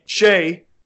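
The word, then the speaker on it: Skjei: They didn't only give up – they signed Kreider, which is good, but they gave up Brady – how do you pronounce that last name? – Shea.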